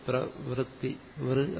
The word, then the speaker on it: പ്രവൃത്തി: വകാരത്തിനോട് ഋകാരം ചേർത്തിട്ടാണ്, സ്വരം ചേർത്ത് പ്രവൃത്തി വൃ എന്നാണ്. പക്ഷെ മലയാളത്തിൽ നമ്മൾ അറിയാതെ ഉച്ചരിക്കുന്നത് വകാരത്തിനോട് ശേഷം രേഫം ചേർത്തിട്ട് പ്രവർത്തി എന്നാണ്, പ്രവർത്തി.